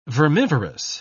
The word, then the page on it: vermivorous: British English